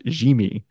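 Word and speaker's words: Yimi: Well, the fact that they traded for Garcia, whose first name is apparently pronounced Jimmy.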